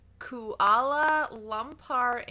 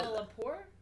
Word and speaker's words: Kuala Lumpur: Malaysia Airlines Flight 370 departed Kuala Lumpur International Airport.